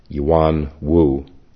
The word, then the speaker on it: Yuan Wu: I'll read just a little bit of biographical material about Yuan Wu. It's spelled Y-U-A-N, Yuan Wu, W-U.